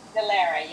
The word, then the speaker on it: Dellera: Dellera, yes.